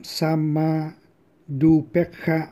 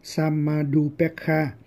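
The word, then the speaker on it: Samādhupekkhā: Rồi thì tiếp tục là những cái pháp giác chi khác, bao gồm cả sama dupekha bojangga. Thì đây là sama dupekha, thì có samadhi cộng upekha, gộp lại thì đọc là sama dupekha, tức là samadhi tức là định, và upekha là xả.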